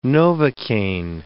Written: Novocain

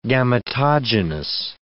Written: gametogenous

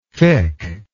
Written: fikh

